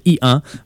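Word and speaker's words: EO: Ben, ça s'écrit E-O, mais ça se prononce I-1.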